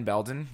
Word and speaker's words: Belden: I am Ben Belden. You can find me on Twitter at RealBBelden. That's B-E-L-D-E-N on the last name.